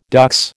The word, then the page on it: dux: American English